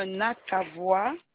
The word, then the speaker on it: Nakavoie: c'est madame Nakavoie. – Ça s'écrit comment? – N-A-K-A-V-O-I-E.